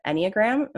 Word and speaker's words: Enneagram: I know obviously you're not an expert, but I love how passionate you are about the Enneagram.